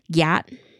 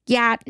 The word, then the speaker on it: GYAT: Gyat. Spelled all caps GYAT. Gyat. Gyat.